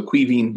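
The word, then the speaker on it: Caoimhín: He frenetically spelled it K-W-E-E dash V-E-E-N. So Queefin. But The English people say Queefin. So. – It's spelled C-A-O-I-M-H-I-N.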